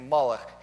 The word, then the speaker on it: Molech: From Melech, king, to Molech, shameful king.